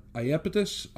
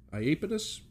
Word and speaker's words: Iapetus: but Iapetus, Iapetus, but in any event, I-A-P-E-T-U-S, who was a fellow Titan and his brother.